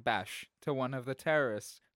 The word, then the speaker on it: Beige: Let's hear how this is pronounced. – That's what Vader was really yelling when he told him that Padme had passed. Beige. – Bash. Bash.